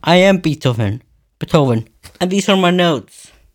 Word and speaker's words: Beethoven: Beethoven.